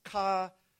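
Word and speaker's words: kollaō: It literally is kolaoa. It's a difficult word to pronounce. Kolaoa. It means to be glued together.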